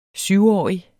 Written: SYVOGTYVE

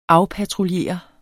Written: afpatruljere

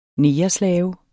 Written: negerslave

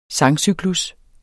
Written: sangcyklus

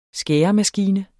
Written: skæremaskine

